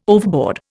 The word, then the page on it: overboard: Indian